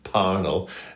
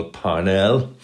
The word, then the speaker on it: Parnell: In April of that year, a young Church of Ireland landowner, Charles Stuart Parnell, and I was taught as a history student in Trinity that I must pronounce him Parnell. Apparently that's what he would have said himself, rather than the more usual Parnell.